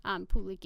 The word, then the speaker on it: Puli: First, there are those dreadlocks, the instantly recognizable feature of the Puli, pronounced Puli. Pulik is the plural, like we said.